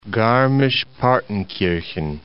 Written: Garmisch-Partenkirchen

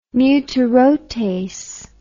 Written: mutarotase

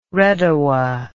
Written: redowa

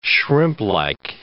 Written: shrimplike

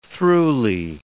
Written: throughly

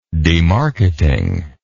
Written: demarketing

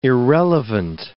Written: irrelevant

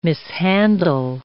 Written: mishandle

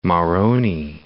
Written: Moroni

